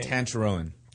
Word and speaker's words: Tancharoen: Tancharoen.